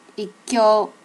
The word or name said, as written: ikkyo